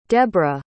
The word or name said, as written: deborrah